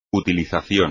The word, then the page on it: utilización: espagnol